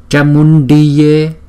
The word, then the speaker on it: Chamundiye: Chamundiye Phet. Chamundiye Phet. Chamundiye Phet. Chữ này, chữ C này chúng ta đọc là cha.